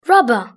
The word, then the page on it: rubber: British English